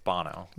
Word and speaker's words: Bono: Bono and The Edge. We're like, oh. – I believe it's pronounced Bono.